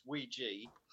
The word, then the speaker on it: Ouija: So I found out the Ouija board, I should pronounce it Ouija, even though it looks like Ouija, it's Ouija.